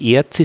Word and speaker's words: Erz: wenn es aber irgendwas mit Erz ist, dann muss man es mit langen geschlossenen E aussprechen. Erz kommt aus der Erde, das Erz ist der Erste.